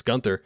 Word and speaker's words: Gunther: But if you're from the United States, you want to say Gunther, there you go. It's Gunther because that's how you would pronounce it in the English language.